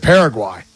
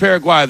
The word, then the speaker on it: Paraguay: Are we calling it Paraguay or Paraguay? – So I always pronounce it Paraguay. – Okay, I'll rock with Paraguay then, because it doesn't rhyme with Turkey A and USA, and I'm not a rapper, and so I don't need to be rhyming everything.